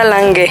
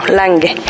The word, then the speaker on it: Lange: Bueno, primero te digo, es Nora Lange. – Lange, perdón. – No, claro, porque es noruega, entonces cuando conocí a su heredera, le pregunté cómo se dice, por favor, Lange, Lange, y me dijo, no, Lange.